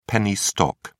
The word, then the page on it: penny stock: British English